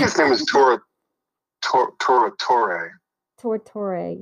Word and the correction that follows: Giuseppe Tornatore: yeah. – I'm not sure how to pronounce his name, but it's a great film. – Yeah. Okay, so perfect. So I'm looking at it as they still... – Giuseppe Tornatore. – Giuseppe, yes, Giuseppe Tornatore.